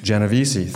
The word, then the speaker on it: Genovese: That concept was studied heavily in the case of Catherine Kitty, how do I say that last name? – Genovese, thank you.